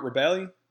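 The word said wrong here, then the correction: Rebillet: Rebellit? – Rubier. – Rubier, what?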